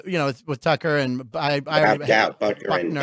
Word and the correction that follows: Butker: I always butcher his name, even though he won the Super Bowl. – Harrison Butker. – I always mess up his last name.